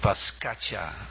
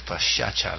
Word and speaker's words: Paschacha: You were mentioning about pronunciation, and I hear many older devotees, they still sing Paskacha instead of Paschacha, like Chaitanya.